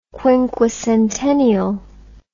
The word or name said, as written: quinquecentennial